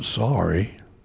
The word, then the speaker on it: Syria: S-Y-R-I-A. Some people pronounce it sorry, sorry.